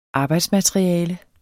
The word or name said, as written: arbejdsmateriale